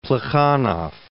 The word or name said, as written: Plekhanov